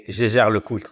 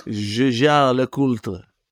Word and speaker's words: Je jure le culte: I would say Je jure le culte. – Je jure le culte.